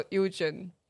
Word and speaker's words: Eugeon: 我们今天邀请到 Eugen，可以这样发音吗？ – 嗯，可以可以。